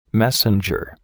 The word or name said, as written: messenger